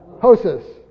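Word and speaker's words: Hosos (ὅσος): Hosos. It's H-O-S-O-S. Hosos. Hosos.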